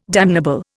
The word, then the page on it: damnable: Indian